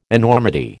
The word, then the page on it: enormity: American English